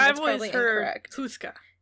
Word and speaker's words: Houska: So that's one of the pronunciations the most common one that I found, but there are other pronunciations that are Hauska. But I feel like it's in um, the Czech Republic, and I feel like for some reason Hauska is Americanized and Houska is how they would pronounce.